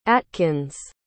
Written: attkins

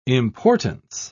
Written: importance

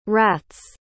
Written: rat's

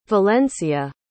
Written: valencia

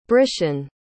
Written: brishen

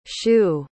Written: schuh

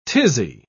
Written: tizzy

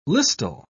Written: listel

